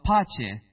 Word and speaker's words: pace: So pace. It's two syllables.